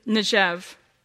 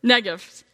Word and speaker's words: Negev: So Abram left Egypt and traveled north into the Negev. I'm not saying that right. Right? – Negev.